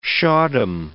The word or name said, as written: shahdom